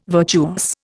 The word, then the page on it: virtuous: Indian